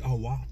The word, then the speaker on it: Oahu: And Oahu. – Oahu. – Go to Maui and Oahu. – That sounds fancy. – Facts. Oahu.